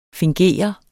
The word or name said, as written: finger